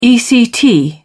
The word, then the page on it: ect: British English